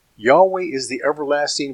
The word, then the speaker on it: Yahweh: that name was written in the Hebrew as four letters, Yod, He, Vav, He, pronounced as Yahweh in English, although we can't be sure of the exact dialect ancient pronunciation.